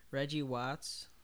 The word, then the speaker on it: Reggie Watts: Yeah, I like Reggie Watts.